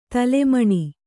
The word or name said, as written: ತಾಲಮಾನ